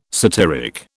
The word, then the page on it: satyric: British English